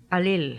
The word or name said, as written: Alil